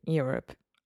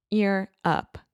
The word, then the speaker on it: Europe: People will be in Europe. – Yeah. Like it's easier. – Yeah. Can we focus one second on the pronunciation of the word Europe? – Europe.